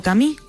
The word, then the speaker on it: Camus: Narrado por nuestros compañeros de Un libro, una hora, inaugura la primera novela del escritor argelino-francés Albert Camus, o Camus, como se pronuncia en su idioma natal.